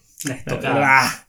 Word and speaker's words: estocada: El estacado. en la, estocada. La, pronunciación. La estocada. La estocada final, gracias.